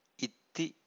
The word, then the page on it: itthi: Pali